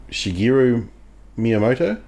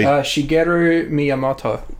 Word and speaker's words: Shigeru Miyamoto: So it's Shigeru Miyamoto? I butchered that, but anyway. So Shigeru Miyamoto. – That's better.